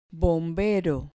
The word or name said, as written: bombero